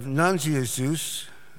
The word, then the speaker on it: Nazianzus: And even back in the late 4th century, Gregory of Nazianzus, that's how it's pronounced, sounded equally befuddled, asserting that Jesus began his ministry by being hungry.